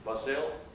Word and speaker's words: Basel: How do you pronounce it? B-A-S-I-L. Basel or Basel? You know? – Basel. – Basel? Switzerland.